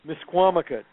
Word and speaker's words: Misquamicut: Is it pronounced Miskamakut? – Misquamicut, yes.